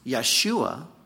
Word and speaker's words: Yeshua: The angel says to Joseph, you are to call his name Jesus, Yeshua would be the Hebrew pronunciation, for he shall save his people from their sins.